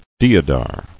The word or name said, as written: Deodar